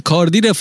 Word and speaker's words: کارتیه: آره من شعرش اصلا یادم نمیاد، کاردی اشاره به کاردی بیه یا به کاردی شدنه؟ – پلی بو کاردیه. – آه کارتیه اون کارتی نوشته، ولی خب بین ت و د تلفظ میشه کاردی.